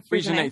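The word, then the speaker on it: Freixenet: Freshenet, is that right? – Frisianet. – Thank you.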